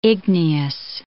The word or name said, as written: igneous